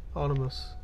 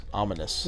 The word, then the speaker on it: ominous: A little omnibus there. – Omnibus? – How do I say that? I'm so tired right now. – Ominous. – Ominous. That's not saying it. – Ominous. – Ominous. Yeah. There it is.